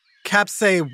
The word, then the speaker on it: capsaicin: Capsaicin what? – It's pronounced capsaicin.